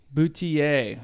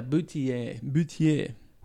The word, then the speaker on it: Boutilier: And so how do we say this name again? – Boutilier. – Yeah, Boutilier.